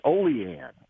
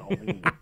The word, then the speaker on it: Olean: It's Olean. It's not, it's not Olean.